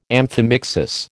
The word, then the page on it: amphimixis: American English